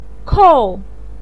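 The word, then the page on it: kou: tone 4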